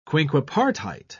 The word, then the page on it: quinquepartite: British English